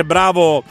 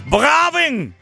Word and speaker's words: brav: No, no, non si dice bravo, bravo, sehr gut, brav. – Aspetta, perché ci provo, Sehr gut, braha. – Brav. – Ah, brah, ho capito. – Brav. – Con l'H finale.